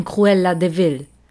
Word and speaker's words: Cruella de Vil: Deswegen nicht de, also klar, ausgesprochen Cruella de Vil. Aber es ist eigentlich Cruel Devil.